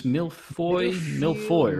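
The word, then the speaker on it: mille-feuille: How do you pronounce this? Milfoy, right? – Yeah.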